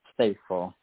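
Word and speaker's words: Stacil: Stateful. – Spell it for me. – It's like, it's STA. C-I-L. – Okay, got it. – It's like Stacy, but yep.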